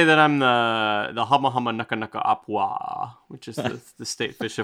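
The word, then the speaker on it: Hama Hama Naka Naka Apua (humuhumunukunukuāpuaʻa): I think that's how you say it. Hama Hama Naka Naka Apua. – That is exactly what I was going to say.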